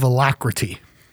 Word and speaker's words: alacrity: Well, alacrity is a four-syllable word, A-L-A-C-R-I-T-Y.